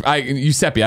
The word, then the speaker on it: Giuseppe: Giuseppe. I put too much on the G on the front. My apologies.